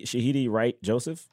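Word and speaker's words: Shahadi Wright Joseph: Is it Shahidi? – Shahidi Wright Joseph.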